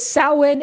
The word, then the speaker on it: Samhain: It looks phonetically weird, but I'm pretty sure it's Samhain. – Okay, well, it is Samhain. It is spelled Samhein.